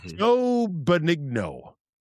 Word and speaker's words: Benigno: Speaking of back in my day, Joe Benigno.